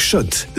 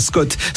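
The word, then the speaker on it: Schott: Oui, gagné sur RMC avec Shot, la marque iconique new-yorkaise depuis 1913. Oui, Shot. Et pas Scott, ça fait des années qu'on prononce mal la marque.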